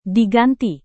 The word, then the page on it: diganti: indonesian